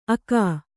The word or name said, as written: ಅಗ